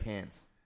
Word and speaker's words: Cairns: It's spelled C-A-I-R-N-S. It looks like Cairns, but they said Cairns.